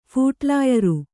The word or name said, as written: ಫುಟ್‍ಲಾಯರಿ